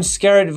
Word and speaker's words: Skerritt: Skerritt Viper was in the first, or somewhere around the age being about the same along the lines.